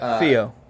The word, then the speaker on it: Theo: But, Theo. That's how they say it over across the pond. I don't know if you knew that.